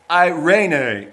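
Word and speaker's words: eirene (εἰρήνη): The Greek word for peace is eirene. It's E-I-R-E-N-E. Now, we call it like serene. We get our word serene, and the Greek is pronounced eirene 92 times, New Testament.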